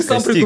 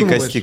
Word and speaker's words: кости: Э, Ударение правильно поставил? – Кости или кости? – А, я говорил кости, но меня, по-моему, как-то поправили кости. Я такой, ну окей, ладно, кости.